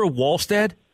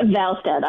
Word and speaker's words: Wallstedt: Is it Jesper Wallstedt? – Jesper Wallstedt,